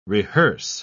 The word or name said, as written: rehearse